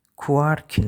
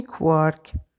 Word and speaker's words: کوارک: کلمه کوارک ظاهرا باید مثل کوارک تلفظ شه با این تفاوت که به جای ت کاف در انتهای کلمه است اما معمولا مثل لارک تلفظ می گردد.